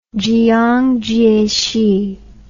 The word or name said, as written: Jiang Jieshi